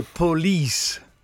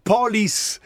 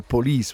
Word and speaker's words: police: In realtà qui fanno l'accento, mettono l'accento per questione di metrica quasi un po' sbagliato, perché in realtà si dice police, non pol, sembra che dicano police on my back, invece in realtà sarebbe police, perché l'accento nella parola inglese cade lì.